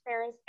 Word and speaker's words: Faris: Now, my name is spelled Tari, T A R I, and then it's Faris, F A R I S, which I know is very unique And both of them.